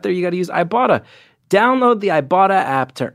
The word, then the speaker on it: Ibotta: Have you heard about Ibotta? That is Ibotta. That's spelled I-B-O-T-T-A.